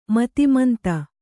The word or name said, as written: ಮತಿಮಂದ